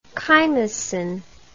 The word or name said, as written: chymosin